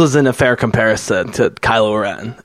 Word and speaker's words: Kylo Ren: Kylo Ren.